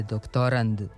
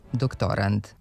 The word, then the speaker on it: doktorand: Za onog ko se sprema za doktorski ispit, ko je kandidat za doktorski stepen u nauci, kažemo da je doktorand sa krajnjim D, a ne T. Kako je reč o zvučnim parnjacima, nekad nije sasvim jasno koji smo glas čuli, ali kod akcenta nema dileme, ne bi trebalo da bude na poslednjem slogu.